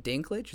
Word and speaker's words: Dinklage: Apparently, so Peter Dinklage, is that how you pronounce his last name? – Yeah, yeah.